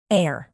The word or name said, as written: heir